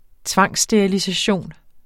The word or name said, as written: tvangssterilisation